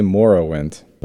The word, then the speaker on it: Morrowind: Morrow. Like tomorrow. – Is it Morrowind? – I say Morrowind.